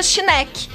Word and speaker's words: Schneck: Ela é uma herança alemã e se pronuncia Schneck.